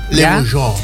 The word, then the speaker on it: Le Bourgeois: Este sábado, ya en el centro de evento Le Bourgeois. No sé si lo dije bien o no. – Le Bourgeois.